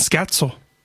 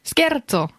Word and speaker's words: Scherzo: Ich habe es mein ganzes Leben lang ein Scherzo genannt. – Ja. – Weil so spricht man ja auf Deutsch. – Ja. – Aber auf Deutsch benutzt man auch den italienischen Begriff. Das heißt, streng genommen ist es ein Scherzo.